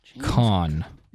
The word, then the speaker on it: Caan: Just got a news alert that said Godfather star James Caan has passed away. – Caan, is that how you say it? James Caan.